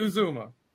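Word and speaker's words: Uzomah: I don't know how to pronounce his name. – I think Uzuma. It's Usman. – Uzuma? – Uzuma or something like that. – CJ Uzuma. – Uzuma. – Okay, yeah, CJ Uzuma.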